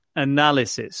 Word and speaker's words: analysis: analysis. So analysis. No. Repeat after me, Honza. The stress is on the second syllable. Analysis.